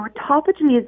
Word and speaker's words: autophagy: So can you explain to them what autophagy is and why it's so important? – I sure can. So autophagy is I'm so impressed that you got the word sounded out correctly, because it's a really strange word.